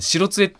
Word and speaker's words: 白杖: りんごさんからのメールで、今ちょっと気になったと思うんですけどこのボイスオーバーがですねそうですね白杖をですね白杖って読むん